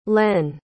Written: lenn

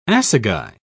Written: assegai

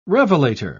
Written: revelator

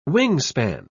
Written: wingspan

